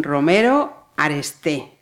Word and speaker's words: Romero Aresté: Eh, y me queda también Giselle mm, Romero Aresté.